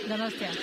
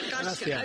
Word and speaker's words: Donostia: Donostia. – Donostia.